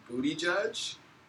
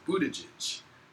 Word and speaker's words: Buttigieg: But one of my favorite people and listener to this podcast, she used to have the Twitter handle Pete Booty Judge because that's really how his name is pronounced, Buttigieg.